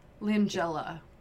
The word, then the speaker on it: Langella: Uh, Frank La Langella.